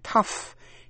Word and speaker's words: tough: Chính tả lối Mỹ, P-L-O-W, plowman, người thợ cày. G-H câm. O-U-G-H còn đọc là uff trong chữ tough, T-O-U-G-H, tough.